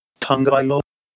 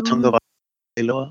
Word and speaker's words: Tagovailoa: I believe they pronounce his name Tagovailoa, if I remember correctly. – Is it? – Tua Tagovailoa.